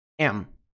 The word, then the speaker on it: them: Qual que é a redução de them? Tira o TH. E soa como am. I don't want them. Preste muita atenção. Repete aí. I don't want them. Pronúncia completa. Want them. Want them. Quando reduzido, tira o TH. Fica am.